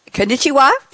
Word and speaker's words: konnichiwa: Well, I'm safe saying konnichiwa. Did I say that right? Uh, absolutely.